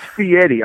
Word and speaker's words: Fieri: It's Fieri, all right? You have to say it correctly.